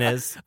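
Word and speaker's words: Innes: Is that how you say it? Inez.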